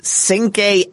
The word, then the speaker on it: Cinque: But her lawyer, whose name is Cinque Axum, I think that's how you pronounce it. It's C-I-N-Q-U-E. It's the first name, Cinque Axum, A-X-A-M.